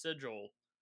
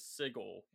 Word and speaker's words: Sigil: And if you're listening and you're wondering, like, no, I'm pretty sure it is pronounced Sigil. No, it's actually the pronunciation of the city, according to actual Planescape lore, is Sigil with a hard G.